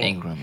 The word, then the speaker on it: Ingram: Ingram.